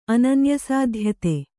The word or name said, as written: ಅನನ್ಯಸಾಧ್ಯತೆ